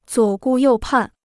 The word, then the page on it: 左顾右盼: zuǒ gù yòu pàn